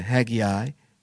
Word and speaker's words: Haggai: Read Haggai, or some people pronounce it Haggai, chapter 1.